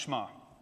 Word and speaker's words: Shema: That's how you pronounce it, the Shema. It's spelled Shema, it's pronounced Shema.